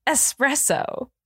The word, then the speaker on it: espresso: However, you might not be able to spell it because you do pronounce it incorrectly. It is espresso. – E-X. E-S-S-P-R-E-S-S-O.